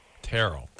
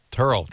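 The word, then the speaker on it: Tearle: That's Terrell. Terrell. – Terrell. – Whatever. – Like Daryl, but with a T.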